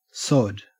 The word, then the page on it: sod: Australian English